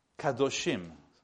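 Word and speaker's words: Kadoshim (קדושים): Kadoshim, spelled with Q or K.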